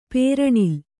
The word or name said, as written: ಪ್ರಣಾಲಿ